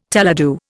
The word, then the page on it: teledu: Indian